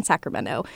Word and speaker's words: Sacramento: I also spoke with a linguist who told us about why Sacramentans do not pronounce, or I guess Californians do not pronounce the T in Sacramento.